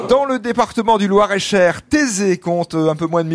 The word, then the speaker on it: Thésée: Thésée, c'est une commune agricole et surtout viticole.